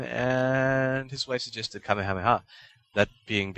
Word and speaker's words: Kamehameha: Yeah, well, the pronunciation of the attack in Dragon Ball kind of lies along Japanese phonology. And so, it also really goes with the Kame part. – Yeah, Kamehameha. And so you kind of have the first syllable, Kame, set up the intonation of the next two. – Right. – Well, the next two parts. And then Ha is kind of, well, it stands on its own anyway, so there you go.